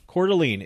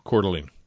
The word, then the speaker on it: Coeur d'Alene: How's it pronounced? – Coeur d'Alene. – Coeur d'Alene. It's spelled C-O-E-U-R, and then D, and then apostrophe, whatever. – It's an apostrophe. – It's called. Then A-L-E-N-E.